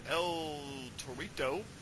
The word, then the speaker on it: El Torito: We have our next match of the evening, which is Los Matadores with El Torito, which rhymes with Dorito.